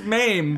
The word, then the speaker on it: MAME: You get a mommy? – A mommy emulator. – Are they called MAME? – Mommy. – I don't know how to pronounce these things. I see these words. – It's MAME, but I see these words on the internet.